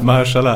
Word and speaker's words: Mahershala: Man muss schon sagen, die dritte Staffel fand ich schon, war, ich glaube, mit Mahershala Ali? Nein, wie spricht man den aus? – Er war Close Enough. – Mahershala Ali.